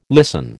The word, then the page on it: listen: American English